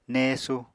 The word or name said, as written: néesu